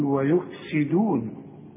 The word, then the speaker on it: ويفسدون: فالسين دي مكسورة تقول ويفسدون، والدال مرققة دائما سواء مضمومة ولا مفتوحة ولا مكسورة.